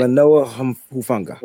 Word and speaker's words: Talanoa Hufanga: Talanoa Hufanga.